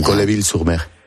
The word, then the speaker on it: Colleville-sur-Mer: En Colleville-sur-Mer.